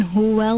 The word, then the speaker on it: huwa: Again, the waw has a fatha on it. When we pronounce the fatha, we need to open our mouth. Ah, fatha literally means to open. Ah, huwa. Qul huwa Allah.